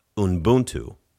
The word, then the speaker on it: Ubuntu: Like when you talk about Ubuntu, right?